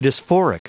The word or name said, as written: dysphoric